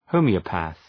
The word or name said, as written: homoeopath